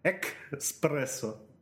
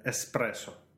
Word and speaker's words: эспрессо: Экспрессо. Экспрессо. Да, такая. Кофе называется эспрессо. Эспрессо. Не надо говорить экспрессо.